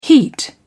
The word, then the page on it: heat: British English